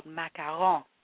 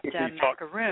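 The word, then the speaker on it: macaron: And the French ones are actually called macarons, and it's not macaroon.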